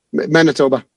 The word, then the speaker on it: Manitoba: I'm going to be in 150 stores in Manitou. How do you pronounce it? – Manitoba.